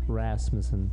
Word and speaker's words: Rasmussen: I know now that I kind of butchered your last name then. I kind of said Rasmussen, I think. – It's open to interpretation. – Rasmussen. – Lots of different ways to do it.